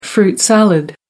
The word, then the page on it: fruit salad: American English